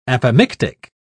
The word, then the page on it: apomictic: British English